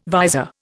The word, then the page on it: visor: Indian